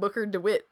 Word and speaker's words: Booker DeWitt: really have to play as a character named Booger DeWitt? – Booger DeWitt? – Booger? – Booger. – Wait, how do you pronounce it? – I said Booker DeWitt, Bill. – It's Booker? – Booker.